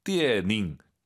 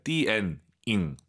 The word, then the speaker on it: 电影: 자, 띠에닝, 띠에닝 절대 안 됩니다. 연음, 니음 발음 들리면 안 돼요. 띠엔잉 들어볼게요.